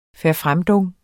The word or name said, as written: verfremdung